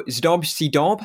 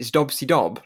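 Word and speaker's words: Zdob și Zdub: It's meant to sound like a drum kit, isn't it? Zdob Cdob, like how you would hit a drum, a cymbal and a drum.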